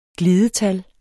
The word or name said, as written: glidetal